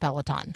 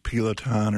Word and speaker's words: Peloton: Apparently, Peloton has a problem, and some people have a problem with Peloton. – Yeah. And good job pronouncing that. I tend to kind of pronounce it wrong, like Peloton or something like that.